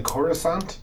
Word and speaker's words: Coruscant: Let's say he just got back from the Corsicant. Big race there. – Is that like Coruscant? – Yes, that's how it's pronounced.